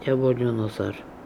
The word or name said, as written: iabolioonozor